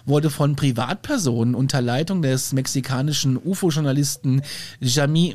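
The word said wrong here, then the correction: Jaime: Chaim, Chaim.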